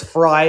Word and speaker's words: Frei: But phonetically, it's like the two words, fry and height.